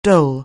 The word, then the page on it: dole: anglais